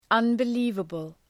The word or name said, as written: unbelievable